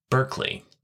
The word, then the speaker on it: Berkeley: That last name is spelled Berkeley, like the hoity-toity Bay Area, California town whose world-class university we hope and pray that awkward Jesuit will eventually attend. But the philosopher's name is actually pronounced Berkeley, like the round mound of rebound.